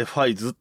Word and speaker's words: 555: えっ、ー、と、仮面ライダーファイズって、えっ、ー、と、 555 ってか書いてファイズって読むんですよ。